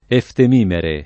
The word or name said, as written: eftemimera